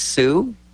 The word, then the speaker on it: Sault: It's easier just to go S-O-O. And it is S-A-U-L-T, and constantly tell people it's pronounced Sioux.